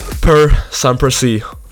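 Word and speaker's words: Per sempre sì: We're now moving to the top 5 with another great song, Per Semper Si, that's how you pronounce it, by Sal Da Vinci, how convenient, representing Italy for this year's contest.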